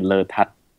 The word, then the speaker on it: Lertad: The very Thai way to pronounce it would be Letad. So the emphasis on the tat.